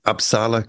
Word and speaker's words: Uppsala: Did I pronounce that uh, Uppsala correctly? Is that how it's said? – About as, co- as close as most Kiwis get it, so it's pretty good.